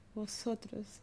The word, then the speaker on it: vosotros: Yeah, I don't know if I say the correct way. Vosotros. – Exactly. – You know better than me. – But you can trust me. It's vosotros.